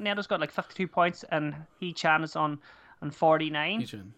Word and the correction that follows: Hwang Hee-chan: Yeah, I mean, I absolutely butchered his name I did there. Hwang Hee-Chan.